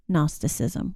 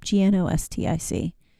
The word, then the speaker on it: Gnosticism: So definitely Peter and also John did a lot of work in the early church against trying to bat down Gnosticism, which has a silent G at the front if you want to look it up. Gnostic.